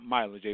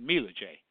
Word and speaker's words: Mila J: And she was talking about herself and the music she does, and I realized that she pronounced her name not Mila J, but Mila J.